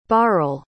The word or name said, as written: bharal